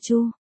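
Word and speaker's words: chu (蛛): Châu hay chu, chúng ta thường quen đọc là chi thù nhưng theo phiên thiết trong từ nguyên thì phải đọc là chu. Châu, mới đúng, người Trung Hoa thường có nhiều âm giống nhau nhưng nghĩa khác nhau.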